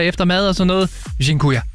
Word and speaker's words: Dziękuję: Og en af dem, som jeg har brugt rigtig meget, det er det, der hedder, og undskyld min udtale, Dziękuję. – Dziękuję? – Dziękuję.